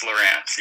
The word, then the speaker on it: LaRance: Yes, LaRance, yeah.